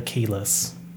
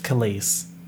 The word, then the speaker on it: Kelis: I actually listened to a lot of Kelis, and in my head, I, for some reason, just want to call her Keyless. Like Keyless entry to a car. But I know she's Kelis.